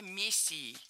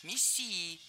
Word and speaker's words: мессией: Он считал себя мессией. Неверно. Правильный вариант – мессией. Мессия – это слово с древнегреческими корнями. Произошло оно от глагола, означающего «мазать», и буквально означает «помазанник». Но и ударение в этом слове падает на второй слог – «мессия».